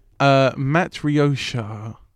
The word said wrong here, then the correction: Matryoshka: Yeah. – Matryoshka.